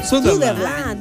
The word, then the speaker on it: Sutherland: Sutherland.